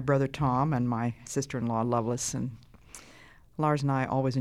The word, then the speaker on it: Lovelace: This is your friend Elizabeth Elliott talking today with my sister-in-law, Lovelace Howard. Her name is spelled L-O-V-E-L-A-C-E. That's her first name, but we pronounce it Lovelace usually.